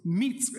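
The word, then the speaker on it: Mitzvah (מצוה): You see it in our English word commandment, because the Hebrew is mitzvah. And you can see I've got it underlined there. There's the tzadeh. Mitzvah is how you would say that.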